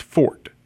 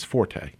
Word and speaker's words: forte: I'd listen to it, and it's, when you use it as saying that it is your personal strength, it is pronounced fort. When you use it in a musical term, it is forte.